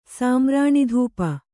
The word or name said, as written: ಸಾಮ್ರಾಣಿಧೂಪ